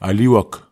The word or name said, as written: aliwok